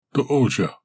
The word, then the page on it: Tozha: Galindri